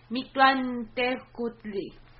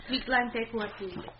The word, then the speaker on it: Mictlantecuhtli: I thought it was a little nod to, And the god of thunder, his name is Mictlantecuhtli. – Thank you. That was way better than mine. Yes. – Mictlantecuhtli. – Yes. With the TL pronunciation, I thought that was really cool.